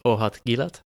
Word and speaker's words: Ohad Gilad: I am your host, and today my guest is Ohad Gilad.